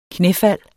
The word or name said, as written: knæfald